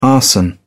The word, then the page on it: arson: British English